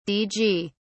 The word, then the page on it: dg: British English